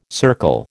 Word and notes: circle: American English

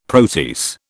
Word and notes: protease: British English

